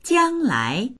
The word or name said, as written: jiang1lai2